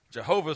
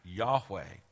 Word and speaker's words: Yahweh: But I think there's a fairly unanimity among scholars today that the best understanding we can have of the pronunciation is, in fact, Yahweh.